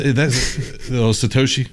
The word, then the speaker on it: Satoshi: Arguably could be their Tasahoshi, or whatever how you say it. – That's Satoshi, stacking sats.